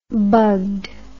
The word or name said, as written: bugged